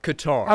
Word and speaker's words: Qatar: Is it Qatar or Qatar? – Qatar.